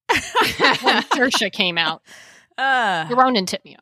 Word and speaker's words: Saoirse: Obviously, the biggest news to come out of 2020 is we all learned how to pronounce Saoirse Ronan. – Yay. Good job. Okay. I do have to note, that in our rundown, you still had to remind yourself that it rhymes with inertia. – Saoirse inertia.